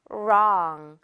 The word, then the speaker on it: wrong: I was wrong. R부터 하는 발음.